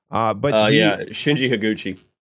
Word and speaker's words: Shinji Higuchi: This movie was directed by, Hideaki Anno and Shinji Higuchi? I'm going to try my best to pronounce these names as close as they're supposed to be. – Shinji Higuchi.